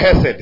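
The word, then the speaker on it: hesed (חסד): Ni neno hesed, inatamkwa, inaandikwa chesed eh? Lakini inatamkwa hesed, sawa?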